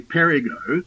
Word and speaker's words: Perigo: When you say mispronounced, we mispronounce it ourselves. We say Perigo, when it should be Perigo. It is Portuguese, and it means danger.